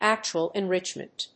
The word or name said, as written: actual enrichment